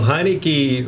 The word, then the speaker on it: Heinicke: You know, Heinicke rhymes with timing.